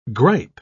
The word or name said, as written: gripe